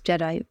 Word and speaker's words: GEDI: By the way, the fund is called GEDI, Gender Equity Diversity Investments, GEDI, a soft G.